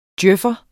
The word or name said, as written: DJØF'er